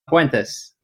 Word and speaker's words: Puentes: Puentes.